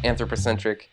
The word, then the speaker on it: anthropocentric: anthropocentric, am I saying that right? – Yeah.